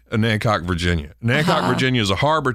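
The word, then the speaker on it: Onancock: Well, you might have noticed that they mentioned the town of Anancock, but they mispronounce it, which everybody who doesn't know better calls it Onacock.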